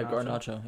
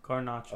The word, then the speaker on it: Garnacho: Yeah, Garnacho. Yeah. – Is it Gar or Gar? Garnacho. – Oh, Car. – No, it's Gar. No, G-A-R. – G-A-R-N.